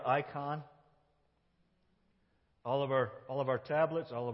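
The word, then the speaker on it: icon (εἰκών): And this word in the Greek, to become like Him, is literally the word, really the word, icon. That's how you say it in the Greek language.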